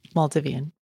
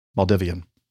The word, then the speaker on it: Maldivian: Just start with Maldivian. – Maldivian. Trans Maldivian Airways.